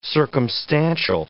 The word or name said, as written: circumstantial